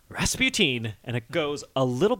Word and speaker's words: Rasputin: I don't trust the people in this song who force Rasputin to rhyme with Queen.